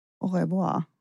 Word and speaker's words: Au revoir: Det är lite svårt uttal. Ö, revoir, Agneta. – Au revoir.